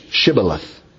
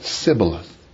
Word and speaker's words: Shibboleth: The Gileadite clans pronounced it with an SH, Shibboleth. But the Ephraimite clans pronounced it Sibboleth. So the difference between sh and s meant someone's life and death.